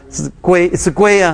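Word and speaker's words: 圭: 因为这个字第一个字我不认识，我不知道怎么念呢，是归啊，OK 啊，归必不离犹大，杖必不离他两脚之间，只等到 OK，那西罗来到，万民都必归顺。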